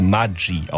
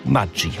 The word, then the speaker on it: Maggi: Eigentlich müsste das Ganze Maggi ausgesprochen werden, italienisch wie Formaggio. Denn Julius Maggi ist zwar in der Schweiz geboren, aber als Kind eines italienischen Einwanderers. Und tatsächlich heißt Maggi in der Schweiz Maggi.